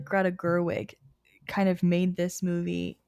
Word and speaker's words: Gerwig: I think that Greta Gerwig, am I saying that right? – Yes.